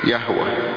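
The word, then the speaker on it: يهوه (יהוה): دول ثلاث صفات مهمين، كلمة الرب إلهك، الرب طبعا انتوا عارفين في العبرانية تتنطق ايه؟ يهوه، ويهوى معناها الكائن، والكائن معناها لا يزول، مطرح ما تروح هتلاقي ربنا كائن هنا هنا هنا هنا هنا.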